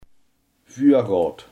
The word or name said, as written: voorraad